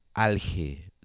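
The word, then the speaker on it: Alhe: The artist is called Alge. That's how I pronounce it in Spanish. But I don't know how would I pronounce this in English. Like Alge, it's A-L-H-E. A-L-H-E.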